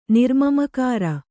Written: ನಿರ್ಮಮಕಾರ

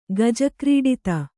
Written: ಗಜಕ್ರೀಡಿತ

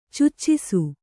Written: ಚುಚ್ಚಿಸು

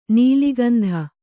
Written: ನೆಲೆಗುಂದು

